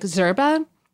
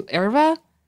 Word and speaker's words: yerba: And yerba, by the way, is a super American pronunciation. In Spanish, it's closer to like zerba. And mm-hmm. in Portuguese, it's closer to erva.